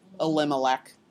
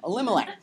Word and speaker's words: Elimelech: Elimelech. That's four syllables. It might be hard for Americans to say that, but I'm sure Caymanians have no trouble with that name. Elimelech.